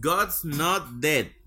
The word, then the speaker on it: God's Not Dead: Y para oye, y para nuestros amigos que los cristianitos que nos odian, tenemos también God's God, ya lo dije mal, God's Not Dead.